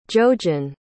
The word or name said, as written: jojen